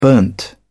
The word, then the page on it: burnt: British English